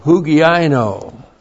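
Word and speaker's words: hugiaino (ὑγιαίνω): And doctrine, didaskalia, is modified by an adjectival participle of hugiaino. H-U-G-I-A-I-N long O. Hugiaino. So it says, hugiaino means to be healthy.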